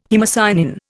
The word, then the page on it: haemocyanin: Indian